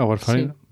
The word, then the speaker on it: warfarina: como la aspirina y la warfarina, no sé si se pronuncia así. – Sí, la warfarina.